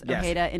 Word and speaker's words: Ojeda: I mean, it is, of course, Ojeda in Mexico, where he's from, but he is assimilated, so he pronounces it Ojeda.